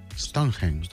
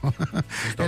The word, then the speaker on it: Stonehenge: Després de ruixar amb pintura taronja el famós jaciment prehistòric, a veure si ho diré bé això, de Stonehenge. – Stonehenge. – Sí. Ho he buscat per veure la pronunciació i ja no me'n recordo. Stonehenge.